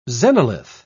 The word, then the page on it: xenolith: British English